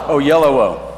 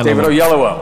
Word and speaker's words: Oyelowo: Oyelowo. – Oyelowo. – David Oyelowo.